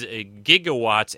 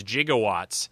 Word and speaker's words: gigawatts: Brown pronounces gigawatts as jigowatts uh, which was actually the manner of a you know physicists would would say it that way sometimes. So they uh, they had him do it that way in the movie too. Instead of again a gigawatt, they, he he'd always pronounce it jig like a, uh, you know a, a wood jig or do dancing a jig.